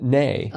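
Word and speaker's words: né: Nay, N-E-E. – Well, I mean, in French, I believe it would be pronounced nay.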